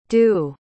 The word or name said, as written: doo